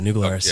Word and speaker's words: New Glarus: Anyway, New Glarus. Am I pronouncing that right? – Yeah, New Glarus.